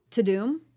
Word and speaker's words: Tudum: It's called To Doom. I don't know how to pronounce it. T-U-D-U-M. To Doom? – To Doom.